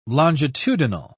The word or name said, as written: longitudinal